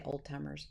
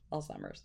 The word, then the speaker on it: Alzheimer's: I used to say old timers, but until I learned, it's Alzheimer's.